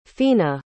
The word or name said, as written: phoena